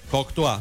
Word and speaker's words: Courtois: Porque sería como un apellido francés. – Sí, claro, porque los belgas tienen mucha descendencia de los franceses. – Se escribe así, Courtois. – Courtois, sí, señor. Entonces, ¿cómo sería? – Courtois. – Y el nombre, Thibaut. – Ti. – Sí, Thibaut. – Courtois. Como de cortar.